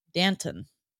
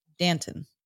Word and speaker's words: Danton: The UCLA pronunciation guide says Danton. – Yeah. – D-A-N-T-T-U-H-N. Danton. Yeah. Danton. Danton. Yeah. – So picture the yogurt and just add a T in the middle.